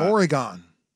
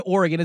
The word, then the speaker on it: Oregon: Do you pronounce it Boston? Everything spelled O-N, everyone pronounces really big things correctly, but when it comes to Oregon, it's like, I don't say Boston. Why do people say Oregon?